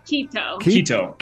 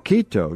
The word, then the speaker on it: Quito: Missionaries in is it Quito or Quito? – Quito. – Quito. No, U. It's Quito, Ecuador. – Right. It's not pronounced with the U.